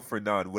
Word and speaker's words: Fernandes: I think your best player is Bruno Fernandes or Bruno Fernand, whatever you want to call it, because that's Portuguese way of pronouncing it.